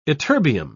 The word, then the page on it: ytterbium: British English